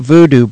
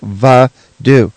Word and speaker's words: voodoo: It's not voodoo, by the way. It's pronounced va-do.